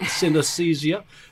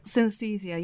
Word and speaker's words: synesthesia: It's synesthesia. Can you tell us what that is and how does that relate to fashion? – Well, synesthesia. Yeah, you did say it right. Absolutely right.